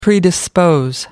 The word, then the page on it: predispose: British English